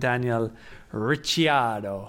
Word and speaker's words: Daniel Ricciardo: Now the problem is how I was pronouncing it was also wrong. So I'm now going to have to call him Daniel Ricciardo. And you have to say it like that as well. – If you put a bit of Italian twang on the end, that's fine.